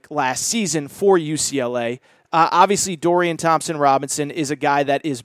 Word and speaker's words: Thompson-Robinson: Again, Dorian Thompson-Robinson, Okay, I did say it right. I was going to say Robbins.